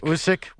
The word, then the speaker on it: Usyk: Did I pronounce that correctly? – Usyk. – Well, that's the Ukrainian pronunciation, Usyk.